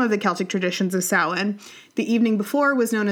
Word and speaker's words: Samhain: Halloween originated with the ancient Celtic festival of Samhain, which is not spelled anything like that, but I promise it's the pronunciation.